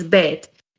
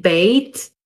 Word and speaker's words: Beyt: While the letter is almost universally pronounced as bet, there are variations on the pronunciation of the letter's name. According to some Ashkenazic pronunciation, for example, it would either be pronounced bait or base, it is one of a handful of Hebrew letters that has its own meaning when attached to other words.